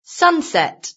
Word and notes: sunset: British English

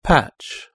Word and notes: patch: British English

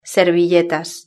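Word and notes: servilletas: European Spanish

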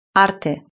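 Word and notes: arte: Spanish (Spain)

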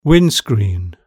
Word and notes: windscreen: British English

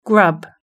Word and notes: grub: British English

